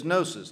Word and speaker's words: gnosis: Now, the Greek word for knowledge is gnosis, G-N-O-S-I-S. The G is silent.